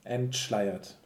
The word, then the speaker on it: entschleiert: I think it's too much if you say entschleiert. It's really too hard to pronounce it. – Mm-hmm. So entschleiert. – Mm-hmm. So it gets a little bit swallowed.